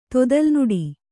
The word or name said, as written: ತೊದಲ್ನುಡಿ